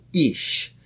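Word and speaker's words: ish (איש): The man, the word for man in Hebrew is called ish.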